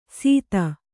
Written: ಸಿತ

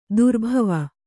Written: ದುರ್ಭಾವ

